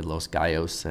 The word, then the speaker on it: Los Gallos: And in front of Soul Food, you heard Los Gallos, and I mispronounced your name before, guys, sorry about that. I pronounced it as a gringo, which I am, but it's really Los Gallos, and my Spanish bride would kill me for not pronouncing it correctly, so hopefully I won't catch any shit for that.